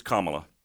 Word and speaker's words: Kamala: Like Pamela, right? – Like, it's not Kamala, it's Kamala. – Kamala. – It's like C-O-M, phonetically here. – A-H-L-A. – Kamala. – L-A. – Yeah. – Kamala. – With the emphasis on the com.